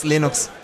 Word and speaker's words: Linux: I pronounce Linux, Linux.